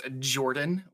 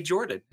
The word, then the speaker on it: Jorphdan: My name is Jordan with a silent PH in the middle. So it's written oddly, but you just say Jordan. It's just a regular great name.